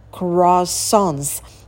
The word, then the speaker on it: croissants: И дальше, внимание, croissants. Вот так произносится безумно это слово. Не croissants, а croissants. Pulls you in.